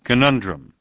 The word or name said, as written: Conundrum